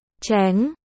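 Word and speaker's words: chéng: Chữ Trình, Cheng, và chữ Thành, Cheng, trong tiếng Trung đều được đọc là Chén.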